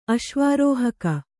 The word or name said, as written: ಅಶ್ವಾರೋಹಕ